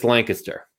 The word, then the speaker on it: Lancaster: Is it Lancaster or Lancaster? – It's Lancaster. – Lancaster? – Lancaster. If you want to sound like an out-of-towner, call it Lancaster.